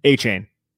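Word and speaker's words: Achane: Or, yeah, A-Kane. We're talking about guys and the Giants. What? – Still wrong. – A-Chain, what is it? – A-Chain. – A-Chain, A-Kane, A-Chain, whatever.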